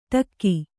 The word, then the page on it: ಢಕ್ಕೆ: Kannada